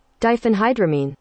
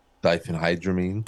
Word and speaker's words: diphenhydramine: diphenhydramine. – That. – Diphenhydramine? – Diphenhydramine. That's what Benadryl is. Diphenhydramine.